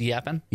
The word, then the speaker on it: Japan: It's pronounced yappin. – Yappin. – The J is crazy.